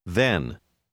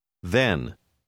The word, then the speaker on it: then: Les lettres TH ont le même son que dans le mot 8, mais ce mot rime avec ten. Then. Then.